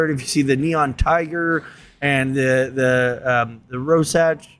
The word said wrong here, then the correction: Rorschach: Ros, what, how do you say it? Rorschach.